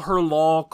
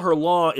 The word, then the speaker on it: Hurlant: Is it Hurlant? You're saying it very ha ha ha. Is it meant to be pronounced like that? – I've always heard it pronounced Hurlant. I've Hurlant. Always heard that the NT is silent.